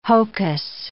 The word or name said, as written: hocus